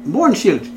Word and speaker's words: Mohrenschildt: You know uh, Georges de Mor- Mohrenschildt? Mohrenschildt, very Is that how you say it right? – Yes. Wow, I finally got it correct.